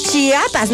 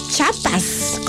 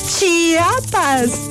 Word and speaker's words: Chiapas: Chiapas, no Chiapas, como dicen algunos por ahí que ni saben pronunciarlo bien. Chiapas.